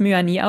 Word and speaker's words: Muani: Also man muss ja auch sagen, selbst mit Kolo Muani, wir sprechen ihn jetzt Muani aus. Er hat gesagt, er wird so ausgesprochen.